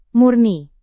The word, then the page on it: murni: Indonesian